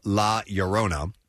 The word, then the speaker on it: La Llorona: In Spanish, two L's is spelled like a Y, or it's pronounced like a Y, right? Okay, yeah. So it'd be The Curse of La Llorona.